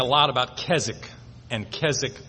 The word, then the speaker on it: Keswick: That word is pronounced Keswick. The W is silent.